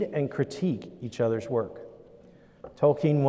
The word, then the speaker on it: Tolkien: Tolkien, or Tolkien, depending on how you say it.